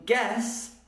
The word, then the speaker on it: guess: But in guess, we don't say it. Guess, you could also write G-E-S-S. So, it's a silent letter, guess.